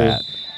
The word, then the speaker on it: AT-AT: How do you pronounce that? – When it's at-at. – It's not A-T-A-T?